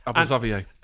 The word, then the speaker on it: Xavier: It's a handball on the line by Abel Xavier. Is that the correct pronunciation? – Abel Xavier. – Xavier.